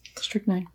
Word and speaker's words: strychnine: Strychnine. Strychnine. Sorry. Have I been saying it wrong No, you said it. Strychnine. Okay. You said it correctly the first time.